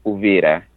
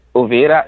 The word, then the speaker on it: Uvira: I used to live in South Kivu, especially a place called Uvira. – How do you spell that? – Uvira, it's U.